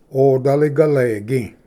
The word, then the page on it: ᎣᏓᎵ ᎦᎴᎩ: odali-galegi